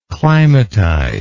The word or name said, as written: climatize